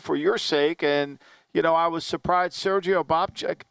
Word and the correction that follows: Sergei Bobrovsky: I mean, is that how you say his name? I don't. – Sergei Bobrovsky, but I like the way you said Bobrovsky.